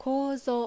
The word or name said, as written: Kouzo